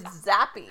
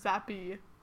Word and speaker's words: Zappe: It's Zappe. But it looks like it's pronounced Zap, but it's Zappe.